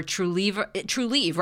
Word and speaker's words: Trulieve: You're also this awesome cannabis ambassador for Trulieve, right? Am I saying it correctly? – Yes. And EO.